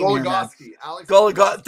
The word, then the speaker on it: Goligoski: Now, Gogliowski, I can't even say his name. – Goligoski. – Thank you.